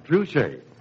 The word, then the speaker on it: Trushay: Spell it T-R-U-S-H-A-Y, Truché.